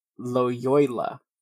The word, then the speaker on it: Loyola: It's almost like Toyota, but it's spelled with an L. Loyola, and approved by Pope Paul III.